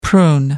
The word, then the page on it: prune: American English